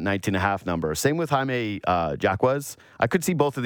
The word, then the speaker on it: Jaquez: But yes, Jaquez, I never get his name right. – Jaquez. Jaime Jaquez.